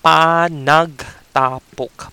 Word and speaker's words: panagtapok: we the one word for it is panagtapok.